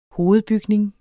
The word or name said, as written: hovedbygning